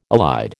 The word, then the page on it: elide: American English